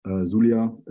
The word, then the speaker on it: Zulia: And as of recently, a couple of weeks ago, Gente Duarte was killed in Zulita, I think. Zulia is how you pronounce it, I guess.